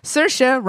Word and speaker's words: Saoirse: Saoirse rhymes with inertia.